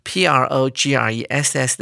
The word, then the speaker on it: progress: 只不过在第三段的这最后一句里面，这个 p r o g r e s s 的字要念成 progress，当做动词来使用，指的是进展、往前进的意思。